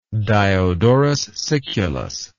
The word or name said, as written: Diodorus Siculus